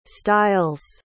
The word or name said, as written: stiles